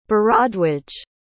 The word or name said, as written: bharadwaj